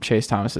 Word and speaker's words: Chase Thomas: No, my name's never really been one of those names that people mispronounce. Chase Thomas is pretty self-explanatory.